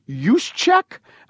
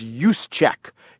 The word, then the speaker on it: Juszczyk: The Harvard guys in question are Matt Burke, who is the center, and his fullback who went to school is Kyle Juszczyk. I think it's Juszczyk. He has an interesting, almost impossible to pronounce name, J-U-S-Z-C-Z-Y-K.